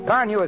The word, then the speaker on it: Carnu: Car New is spelled C-A-R-N-U. Johnson's Car New.